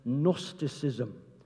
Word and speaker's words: Gnosticism: It probably was an early form of something that in the second century became known as Gnosticism. Gnosticism, it's spelled G-N-O-S-T-I-C-I-S-M. Gnosticism.